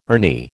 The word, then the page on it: Ernie: American English